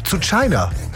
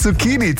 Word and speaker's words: Zucchini: Sowas wie zu China. Ja, statt Zucchini, zu China eben.